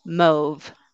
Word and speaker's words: mauve: Okay, so this one is pronounced mauve, similar to grove or trove.